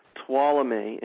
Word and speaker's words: Tuolumne: But if you were to Google Tuolumne, and it's spelled uh, oddly, it's T U O L U M N E, Tuolumne Meadows uh, Poetry Festival, uh, you would find you would find what you're looking for.